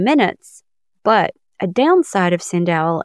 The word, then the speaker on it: SendOwl: I've known people that have used this, and it is spelled exactly how it sounds. SendOwl. Owl, like the nocturnal bird out behind your house in the tree.